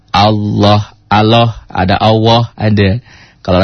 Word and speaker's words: Allah: Allah, Allahu Akbar, gitu. A dulu, W, L, O, H. Itu bunyinya begitu. Kalau tulisan latinnya kan A, L, L, A, H. Allah. Dibacanya jadi Allah, Allahu Akbar.